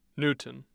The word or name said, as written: newton